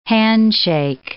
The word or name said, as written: handshake